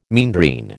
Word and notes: meandrine: American English

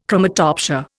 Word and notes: chromatopsia: Indian

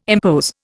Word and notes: impose: Indian

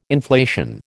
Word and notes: inflation: American English

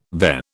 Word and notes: VE: British English

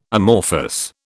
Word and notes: amorphous: British English

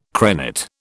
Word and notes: crenate: British English

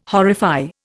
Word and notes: horrify: Indian

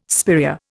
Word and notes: spiraea: Indian

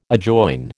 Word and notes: adjoin: American English

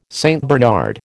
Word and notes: St Bernard: American English

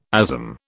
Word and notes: ASM: British English

